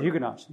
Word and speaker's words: Huguenot: That's the Huguenots. Yeah, sure was. So, Huguenot is correct. Huguenot is the way English people often pronounce it. Yeah, Huguenot is correct. The T's silent.